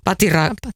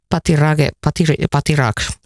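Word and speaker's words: Pathirage: Ja tuossahan nähtiin jo Timanttiliigassakin komea keihäskaari 92-62, mutta sen heitti Sri Lankan Rumesh Sarangapatirich, tai mä en osaa lausua hänen nimensä, mutta voi kohta kertoa, miten se oikeasti lausutaan. – Onko se Patirage? – Patirage, joo, no niin.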